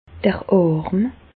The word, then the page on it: d'r Àrm: Bas-Rhin; Reichshoffen